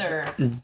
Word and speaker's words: Wunder: It's spelled Vunder. W-U-N-D-E-R.